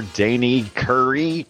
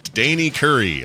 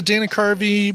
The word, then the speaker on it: Dana Carvey: Oh, of course, I remember Dana Carvey. Can't even say his name, so forget him. – Danny Curry.